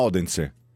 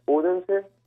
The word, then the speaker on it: Odense: per lo più a Odense, perché ho sentito bene, tu utilizzi questo accento, non Odense, ma Odense. – Sì, ti ho messo un po' anch'io. perché... in danese si pronuncia più Odense. – Esatto, esatto. – Ah, ma è più tipo Odense. – Odense, è una cosa del genere. Adesso... – Esatto, con questa un po' mangiata.